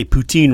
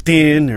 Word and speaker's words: poutine: Apparently, we don't even say poutine right. – No, we don't say poutine right either. – It's poutine or poutine.